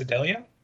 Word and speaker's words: Idalia: Hurricane Idaly. Now, I understand that it's Idalia, but they misspelled it, so I mispronounced it.